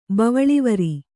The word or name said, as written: ಬವಳಿವರಿ